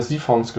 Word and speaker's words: Siphon: In der letzten Folge haben wir über Siphons gesprochen, ohne sie zu erwähnen. Ist es richtig ausgesprochen? – Ja, Siphon. Also ich würde später betonen.